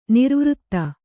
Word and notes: ನಿರ್ವೃತ: nirvřta

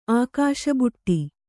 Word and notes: ಅಕ್ಕಸಬಡು: akkasabaḍu

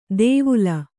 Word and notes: ದೇವುಲ: dēvula

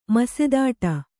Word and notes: ಮಸೆದಾಡು: masedāḍu

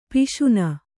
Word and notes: ಪಿಶುನೆ: Kannada

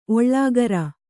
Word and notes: ಓಲೆಕಾರ: Kannada

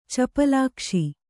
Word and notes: ಚಪಲಾಕ್ಷಿ: capalākṣi

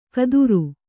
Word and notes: ಕದುರು: Kannada

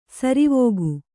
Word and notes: ಸರವಕ್ಕಿ: saravakki

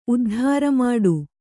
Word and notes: ಉದ್ಧಾರಮಾಡು: uddhāramāḍu

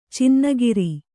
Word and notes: ಚಿನ್ನಕಾರ: cinna kāra

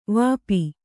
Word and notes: ವಾಪಿ: Kannada

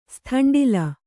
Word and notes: ಸ್ಥಂಡಿಲ: sthaṇḍila

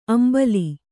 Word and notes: ಅಂಬಾಲ್: ambāle

